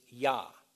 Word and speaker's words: ja: Quick example the German word for yes is ja. Sounds like YA, right? It's not spelled YA, it's spelled JA.